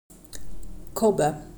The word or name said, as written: kobbe